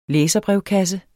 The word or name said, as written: læserbrevkasse